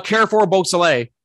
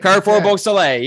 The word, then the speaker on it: Carrefour Beausoleil: How do you say that? – How do I say what? Oh, Carrefour Beausoleil. – Carrefour Beausoleil. – Carrefour Beausoleil. Yep. – All right. Thank you. – That's the way.